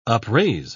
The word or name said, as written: upraise